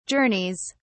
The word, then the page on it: journeys: British English